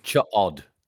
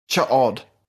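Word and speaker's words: Ch'od: Chod. – Chod. – O-E, so it's not a long O sound.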